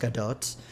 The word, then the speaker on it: Gadot: It's pronounced Gadot. Gal Gadot is a Taurus, and I just think the world needs to be really aware of that.